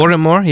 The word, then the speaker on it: Oranmore: Is that how you say it? Oranmore, yeah. If you speak to anyone from Edinburgh, it's something else completely wrong, but yeah, you're right.